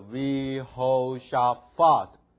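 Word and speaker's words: Vihoshafat (יהושפט): 去 the shoe baguette gada bada havo havu 对 havu v havu hada honem hamo hamu 对不起 hamu hamu 乌木 hamu Has, he save, he rim, bahem, wa wo hu, wa do, ve red, vi ho shafat, 不是 pa, 是 fa. 注意它这个没有一点的，中间读 F，有一点是读 P，对不对？没有一点读 F，所以读 Fat， Fat，啊，注意那个最尾那个 syllable。他那个，Yeah。好，我们再读最后一个字，Vihoshafat。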